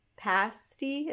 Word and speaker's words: pasty: So I always remember it from there, but it's like in my brain, it's pasty rhymes with nasty, which doesn't make sense. No. But yeah, it's pasty, not pasty. – Because pasty is like, that would, whatever. – But it should be pasty rhymes with tasty.